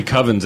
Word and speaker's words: Coven: I was thinking about trying to get some American Horror Story Covens in, because I didn't watch that season, but I've seen that. – That's a good season. It's pronounced Coven, dude. – Coven. – It's Coveen. It's Coveen.